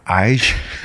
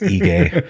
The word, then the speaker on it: Ige: Is it Dan Ige? I was like, no, it's Ige.